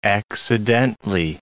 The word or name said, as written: accidently